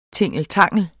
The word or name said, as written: tingeltangel